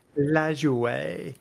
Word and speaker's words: Lajoie: Nap LaJoie, if you want to say it correctly in French.